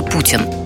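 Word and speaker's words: poutine: Огромные площади, несколько часовых поясов, кладовые природных ресурсов, ну, может, нефти чуть поменьше, медведи, лоси, бобры и даже Путин. Только у нас это президент, а у них национальное блюдо из картофеля фри, сыра и соуса. Но еще ударение на французский манер – путин.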